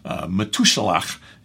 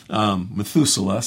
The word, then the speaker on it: Metushalach: Metushalach is how you would pronounce it. Sorry, I got to kind of clean off the lens here. But Methuselah sounds easier in English.